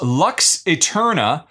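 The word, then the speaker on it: Lux Aeterna: That is Lux Eterna, although it's spelled funny with an A Eterna. A Eterna. – Yes, LUX space AETERNA.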